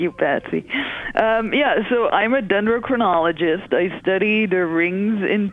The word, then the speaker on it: dendrochronologist: dendrochronologist? – Yes, yeah, absolutely right.